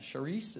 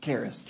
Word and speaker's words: Karis: They have a six-year-old daughter, and Charisse. Is it Charisse? – Karis. – Karis. Sorry. See, I tried to figure that out. It says pronounced Paris with a C.